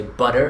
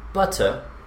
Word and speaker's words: butter: Explain the pronunciation and why you say butter and not butter. – Well, generally in American English, when you have the T sound between two vowel sounds, it becomes a D.